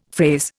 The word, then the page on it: phrase: Indian